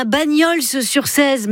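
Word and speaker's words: Bagnols-sur-Cèze: vous avez un Bagnols sur 16, mais on ne on prononce pas le S.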